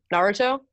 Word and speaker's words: Naruto: Naruto? – Mm-hmm.